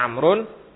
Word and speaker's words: Amr: Maka kalau ada wawunya itu bacanya Amrun. Kalau enggak ada wawunya itu bacanya Umar.